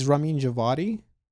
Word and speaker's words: Ramin Djawadi: I think it's Ramin Djawadi. Well, it's almost Ramin like a V Javadi. Sound.